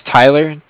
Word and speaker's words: Tyler: My name is Tyler, T-Y-L-E-R. And I'm calling with Cardiff. It's spelled C-A-R-D-I-F-F, as in Frank Frank.